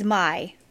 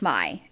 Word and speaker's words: Mai: They either look like Stellan or Maya, however you say her name, or me. – Yeah. – Oh, so it is Mai. It's Mai. So mom's name is Mai. So it's literally like English Mai. M-A-I.